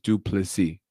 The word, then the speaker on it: Du Plessis: Don't pronounce the last S I heard, right? It's just Du Plessis. That's how you're supposed to say it.